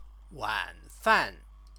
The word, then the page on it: wanfan: Chinese